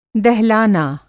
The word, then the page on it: दहलाना: Hindi